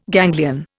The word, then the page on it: ganglion: Indian